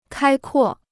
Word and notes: 开阔: kāi kuò

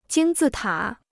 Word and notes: 金字塔: jīn zì tǎ

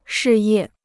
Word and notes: 事业: shì yè

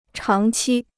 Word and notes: 长期: cháng qī

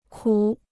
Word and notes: 苦: kǔ

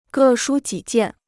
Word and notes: 各抒己见: gè shū jǐ jiàn

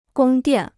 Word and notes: 宫殿: gōng diàn

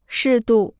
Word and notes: 适度: shì dù

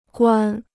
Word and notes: 关: guān

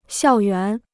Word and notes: 校园: xiào yuán

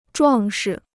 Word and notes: 壮实: zhuàng shi

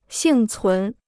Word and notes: 幸存: xìng cún

